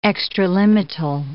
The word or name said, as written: extralimital